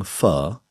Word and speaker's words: Pho: Ganz viele Journalisten und die Leute, die mit Social Media, die fragen mich alle, wie wird nun das Wort ausgesprochen? – Ja. – Wir im Norden, also wo ich herkomme, sagen Pho.